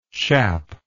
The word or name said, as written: chappe